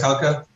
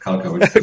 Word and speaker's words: Kalcker: Yes, it's Andreas Kalcker. – Okay. – It's like Walker, but Kalcker.